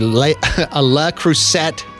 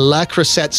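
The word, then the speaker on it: Le Creuset: Somebody said, best item I found at Value Village was a La Crusette. Am I saying that right? – I think so. – A La Crusette square skillet pan.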